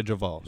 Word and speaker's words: JaVale: It's pronounced JaVale. The V is uppercase. So it's JaVale.